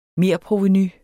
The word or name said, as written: merprovenu